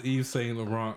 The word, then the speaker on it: Yves Saint Laurent: I didn't know it was Yves Saint Laurent because I don't know fashion like that.